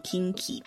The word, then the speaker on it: 近畿: 嗯、那以地方来讲的话呢，会说它是近畿地方，近畿念作 Kinki。